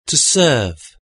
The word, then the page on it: to serve: British English